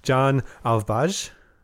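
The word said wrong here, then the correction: Jan Alvbåge: Not even close. – Ah, no. – Apparently, it's Jan Alvboja. Oh. Or Alvboga.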